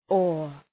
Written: ore

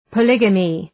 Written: polygamy

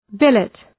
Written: billet

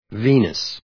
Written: Venus

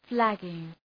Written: flagging

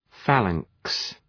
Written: phalanx

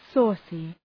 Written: saucy

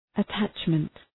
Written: attachment

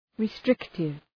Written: restrictive

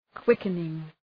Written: quickening

